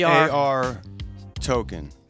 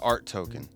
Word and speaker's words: ARToken: So it's AR token, not art token.